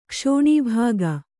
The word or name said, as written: ಕ್ಷೋಣೀಭಾಗ